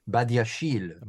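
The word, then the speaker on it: Badiashile: It was pronounced, actually. I heard it pronounced on a French website. – Badiachil. Okay, from now on, he will be known on the Fancast as Badiachil.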